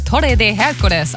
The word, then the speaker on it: Torre de Hércules: Der sogenannte Torre de Hércules, also der Herkulesturm. – Oh, schöne spanische Aussprache.